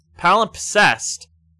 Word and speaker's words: Palimpsest: Palimpsest is the best... – Palimpsest. – No, it's palimpsest. There's S-E-S-T.